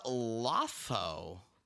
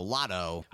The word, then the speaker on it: Lotho: All right, we're going to move on and talk about Lotho. Lotho. – Lotho? – L-O-T-H-O. Lotto. – When have you ever seen T-H and pronounced it Lotho? T-H.